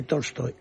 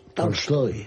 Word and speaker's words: Tolstoy: No es exactamente Tolstoy, es Tolstoy. Tolstoy. – ¿Cómo? – Lo dije. Es Tolstoy con A, una. – Embajador. Lo dije yo así tal cual. Tolstoy. – Correcto, es una es una A que tira hacia el O, pero que es A.